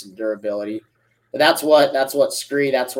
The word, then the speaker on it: SKRE: It is SKRE. It's pronounced SKRE.